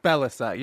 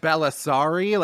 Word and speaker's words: Bellassai: Bellassai, yeah. – Bellassai. – Yeah. – Because it's spelled Bellassai. – Yeah. – Am I right? – It is spelled how it sounds.